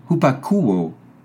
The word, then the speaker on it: hupakouo: hupakouo. Il est translittéré H-U-P-A-K-O-U-O, long O, et signifie entendre.